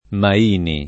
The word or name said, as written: Maini